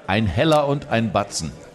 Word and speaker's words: Ein Heller und ein Batzen: Ein Heller und ein Batzen.